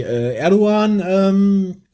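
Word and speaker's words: Erdoğan: Ist aufgefallen durch die Erdogan, das Erdogan-Schmähgedicht. – Sehr gut ausgesprochen, weil das G spricht man im Türkischen nicht aus, wenn es so ein Strich hat.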